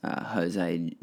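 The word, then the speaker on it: José: I used to pronounce Jose Jos because I was a literate motherfucker, and I think I still am.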